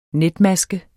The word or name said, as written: netmaske